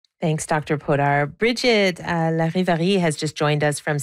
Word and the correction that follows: Larivière: Is it Larivière? – Well, in French, it's Larivière. Yes.